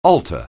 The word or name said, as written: alter